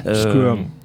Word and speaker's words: Squirm: Donc Squirm en VO, je ne sais pas si je prononce bien. – Squirm. – Comment tu dis ? – Squirm. – Squirm. – Squirm. – Ok, d'accord.